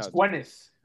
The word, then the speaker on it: Gwyneth Paltrow: Honestly, I can't, like, before we, like, started recording, was I pronouncing the name correctly? Gwyneth Paltrow? – No, Gwyneth. – Gwyneth. Paltrow. Gwyneth Paltrow.